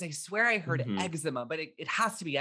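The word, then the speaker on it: Exuma: In the fictional town of eczema, yes, pronounced eczema.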